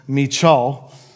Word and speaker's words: Michal: Her name, it looks like, if you read your Bible, it looks like it could be pronounced Michael, but it's actually a Hebrew name. It's actually pronounced Michal.